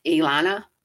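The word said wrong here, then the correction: Iyanla: Okay, Ayanla. – Ayanla.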